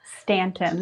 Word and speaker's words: Staunton: And but you not only in super remote places, you're, like I was saying, in two different places, but you started with Staunton, right? – Mm-hmm. Yeah. – Did I pronounce that well? – Staunton. That was pretty good.